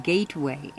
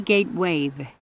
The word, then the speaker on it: Gatewave: We've learned that a lot of you have been hearing our name as Gateway, ending in W-A-Y. That may mean that we haven't been pronouncing it clearly enough. It's really Gatewave, ending in W-A-V-E, like a sound wave or an ocean wave.